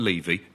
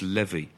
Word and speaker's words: Levy: It's not Levy, it's Levy. – All right, but you know, he's driving Chevy to the Levy.